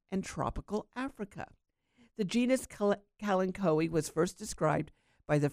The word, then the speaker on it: kalanchoe: And the dirty word of the day is kalanchoe, K-A-L-A-N-C-H-O-E. Now, Americans pronounce it kalanchoe, while the British say kalancho.